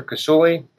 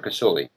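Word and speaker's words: Kousouli: I'm on the uh, social media under uh, Twitter, Instagram, Facebook, under my, my name, Dr. Kasuli. That's spelled D R K O U S O U L I. So Dr. Kasuli.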